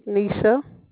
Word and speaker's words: Nisha: Nisha. – Nisha, that's pretty. – Thank you. – How do you spell that? L-E-H-S-A? – N-I-S-H-A.